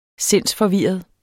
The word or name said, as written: SINDSFORVIRRET